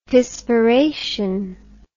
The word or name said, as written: pisspiration